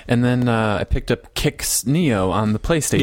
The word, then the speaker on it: Qix: Which I've heard of Kix. I think Nissan Games has done a couple reviews on the game. It's spelled Q-I-X.